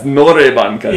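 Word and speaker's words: Nore bankaja (노래방 가자): Dore bankaja? – Yeah. – Like, is that karaoke? But then actually, like, it's the N letter. Yeah. I was saying it wrong the whole first month. Yeah. Nore bankaja.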